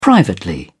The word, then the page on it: privately: British English